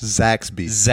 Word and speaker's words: Zaxby's: Zaxby's. – Zaxby's, not to be confused with Saxby's.